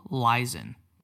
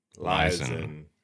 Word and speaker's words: Leisen: All right, I'm getting Mitchell Leeson. – Leeson. – That's how it is. Okay, so I just pronounced it wrong maybe 7 times earlier.